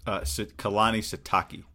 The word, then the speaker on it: Kalani Sitake: Satani? – Kalani Sitake.